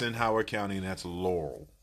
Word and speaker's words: Laurel: There's two syllables to Laurel.